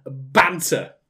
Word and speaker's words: banter: is banter. And you have to say banter by putting emphasis on the first half of like, banter! Like that.